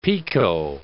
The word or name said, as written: pekoe